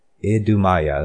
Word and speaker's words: Idumea: Let's see, it's kind of a weird name. Idumea, that's how you say it.